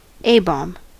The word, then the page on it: A-bomb: /ˈeɪ.bɒm/; /ˈeɪ.ˌbɑːm/